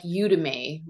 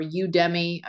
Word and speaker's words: Udemy: So that that plugin type is is that there are also the free or sort of I guess they're free like Udemy, right? Or Udemy. I've heard it pronounced both ways.